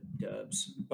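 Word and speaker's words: Doubs: It's really going to be on Christian Watson and Romeo Doobbs or whatever you pronounce his name. Never can figure that out. – Dubs.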